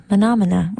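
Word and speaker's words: Menomena: And then I saw an interview, and then the band is called Menomina, which is much more fun to say. I was missing out for so long and not saying it correctly.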